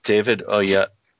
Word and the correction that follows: David Oyelowo: David Oyelowo.